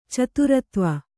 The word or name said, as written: ಚತುರತ್ವ